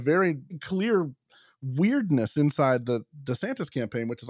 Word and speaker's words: DeSantis: He's demanding that people call him DeSantis rather than DeSantis. Actually, I like Duh better, a nicer flow, so I'm happy he's changing it. He gets very upset when people, including reporters, don't pronounce it correctly.